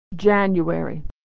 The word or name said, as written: january